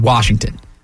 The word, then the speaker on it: Washington: There is no R in the word Washington.